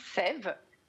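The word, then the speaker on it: Sæve: Donc, effectivement, ça se prononce bien Sève. C'est un diphtongue, le E dans le A, et c'est parce que c'est un petit jeu de mots entre la sève qui va sauver votre peau.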